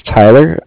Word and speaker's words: Tyler: My name is Tyler. It's T-Y-L-E-R. And I'm calling with Cardiff. It's spelled C-A-R-D-I-F-F, as in Frank Frank.